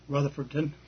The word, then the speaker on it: Rutherfordton: Rutherford. Did I say that right? – Rutherfordton.